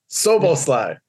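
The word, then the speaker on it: Szoboszlai: And also Soboslai.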